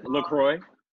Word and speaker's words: LaCroix: How do you say that? LaCroix or LaCroix? – LaCroix.